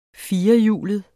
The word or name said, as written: firehjulede